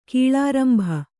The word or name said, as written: ಕೀಳಾರಂಭ